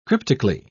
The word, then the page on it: cryptically: British English